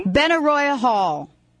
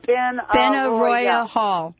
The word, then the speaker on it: Benaroya Hall: How do you pronounce the name of the place where I'm speaking? – Benaroya Hall. – Benaroya. – Benaroya. – Ben Arroyo Hall. – Yes.